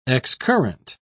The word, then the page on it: excurrent: British English